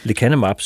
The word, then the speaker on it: Lecanemab: Og for ikke så længe siden, der kom der så den her nye medicin, Lecanemab. – Udtaler ja. jeg det rigtigt? Jeg har aldrig ja, øh, brugt Lecanemab, det ja. – Lecanemab, siger du? – Ja.